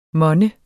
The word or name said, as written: monne